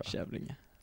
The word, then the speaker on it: Kävlinge: Eller Kävlinge om du uttalar ja, Kävlinge va? Kävlinge. Ja.